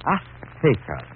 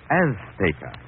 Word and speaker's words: Azteca: Azteca. She gave the Z a TH sound. That's Castilian Spanish. Mexican or any Spanish-American would say Azteca.